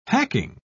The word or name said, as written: hacking